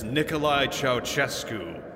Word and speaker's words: Nicolae Ceaușescu: "Join us as we cover the man with the name you can't pronounce without a guide, which, for all of you seekers out there, is Nicolae Ceaușescu.